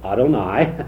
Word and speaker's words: Adonai: Capital L, but then O-R-D in small letters. And the King James and the RSV are very consistent. You pronounced it very well, Adonai,